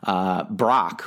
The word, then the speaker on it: Brach: It's spelled B-R-A-C-H, but I believe it is pronounced Brach.